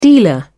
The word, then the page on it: dealer: British English